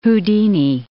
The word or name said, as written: Houdini